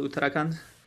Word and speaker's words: Uttarakhand: The city's name is Dehradun, and the state is called Uttarakhand.